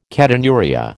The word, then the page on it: ketonuria: American English